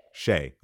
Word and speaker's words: Shea: And I want to make sure I'm pronouncing right. Heaven Sent Shia, correct? – Oh. Heaven Sent Shea. – Shea. Okay. See, this is why I got to ask. – Like Shea Butter Baby.